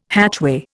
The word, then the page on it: hatchway: Indian